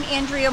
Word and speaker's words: Andrea: I'm Andrea Morganti. – Wait, spell your name out. – Spell that. – A-N-D-R-E-A Morganti.